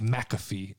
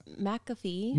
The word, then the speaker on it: McAfee: I think I say that right because I don't think it's McAfee. – It's McAfee. – Maybe it is. McAfee. Pat McAfee. – Like the software I think that's right.